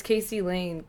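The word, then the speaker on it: Kayce Laine: Oh, it's Casey Lane. Should I spell it? I just started, so I might as well finish. K-A-Y-C-E space.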